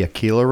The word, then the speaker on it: Aquila: So the one that creeped me out was Beyond the Aquila Rift. I think yes.